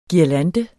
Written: guirlande

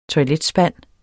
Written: toiletspand

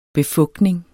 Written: befugtning

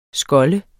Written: skolde

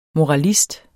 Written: moralist

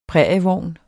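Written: prærievogn

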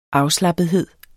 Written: afslappethed

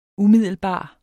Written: umiddelbar

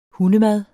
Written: hundemad

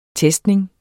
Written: testning

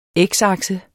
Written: x-akse

